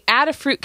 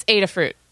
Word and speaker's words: Adafruit: All right, so his idea was a Adafruit keg bot. – Okay, I'm down so far. – Actually, you know what? I said it wrong. It's Adafruit.